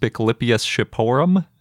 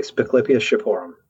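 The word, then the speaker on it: Spiclypeus shipporum: So jumping right in, you named one of my favorite sounding dinosaurs ever just about a year ago, which I think is pronounced Spiclipius shiporum. Is that right? – Yeah, exactly. Spiclipius shiporum. That's how I say it anyways.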